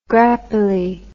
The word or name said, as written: Grappelli